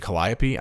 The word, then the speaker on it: Calliope: Is it Calliope? I know I'm off. – I think that's right.